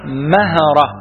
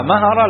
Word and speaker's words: مهرة: مهرة دي تنطق هكذا في الكتب الحديثة، العلماء القدامى كانوا يسموها مهرة بالتحريك الميم والهيه والراء، مهرة دي العلماء القدامى يقولوا عليها بلاد مهرة، والمتأخرين يقولوا عليها بلاد مهرة، فإحنا مع القدامى عشان إحنا سلفيين فإحنا نقول عليها إيه مهرة، وكل خير في اتباع من سلف، فإحنا ننطقها مهرة لأن القدامى كانوا ينطقونها بهذا النطق.